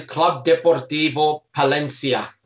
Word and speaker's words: Club Deportivo Palencia: It is Club Deportivo Palencia.